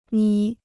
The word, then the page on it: 眯: mí